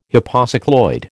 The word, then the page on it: hypocycloid: American English